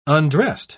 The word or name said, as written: undressed